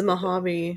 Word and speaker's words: Mojave: And then her last ping of her cell phone was May 23rd in I don't know how to pronounce the Mojave.